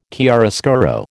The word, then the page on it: chiaroscuro: American English